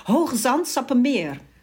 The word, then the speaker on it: Hoogezand-Sappemeer: Ja, Hoogezand-Sappemeer.